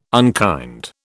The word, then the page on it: unkind: British English